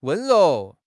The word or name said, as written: wen1 rou2